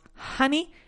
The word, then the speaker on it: honey: Igual que decimos money y no money. Igual que decimos honey y no honey. Right? So it's monkey, money, month, and honey. Aunque sea una O escrito, pues nos da igual. La pronunciamos como una A.